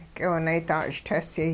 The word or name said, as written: k'iwinaydaa-ch'itesyay